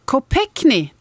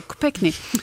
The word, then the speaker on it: Kopechne: och en ung kvinna som jag faktiskt är lite osäker på hur hon uttalar sitt efternamn, Mary Jo Kopechne. – Kopechne, du ser, det sa producenten. Och det gjorde du också, Kopechne.